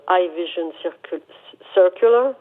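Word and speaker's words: i Vision Circular: Ils ont euh, présenté, ils présentent un concept car, le High Vision Circular, si on veut le prononcer à, l'anglaise.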